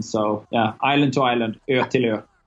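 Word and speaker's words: Ötillö: In Swedish, it would be Ertilö, which translates in English as island to island, which is the basic concept for that particular event.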